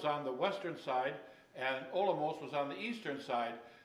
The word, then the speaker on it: Olomouc: Now, I was always pronouncing to myself this town, Olomouc, but the C is, in their language, is an S, more like an S sound, so it's Olomouc, Czech Republic.